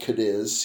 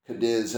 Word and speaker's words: Cadiz: And I remember Cadiz is spelled Cadiz, C-A-D-I-Z, but it's not Cadiz as in Spain. It's Cadiz as in Kentucky.